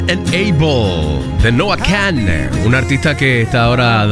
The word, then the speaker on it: Noah Kahan: Noah Kahan, así se escribe, K-A-H-A-N, pero se Noah Kahan, así es que se pronuncia.